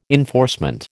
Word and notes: enforcement: American English

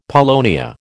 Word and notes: paulownia: American English

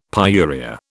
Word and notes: pyuria: British English

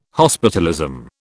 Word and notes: hospitalism: British English

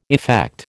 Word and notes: effect: American English

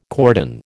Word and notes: cordon: American English

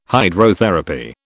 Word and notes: Hydrotherapy: British English

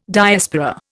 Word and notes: Diaspora: Indian

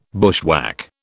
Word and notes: bushwhack: British English

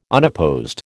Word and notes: unopposed: American English

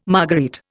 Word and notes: marguerite: Indian

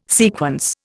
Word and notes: sequence: Indian

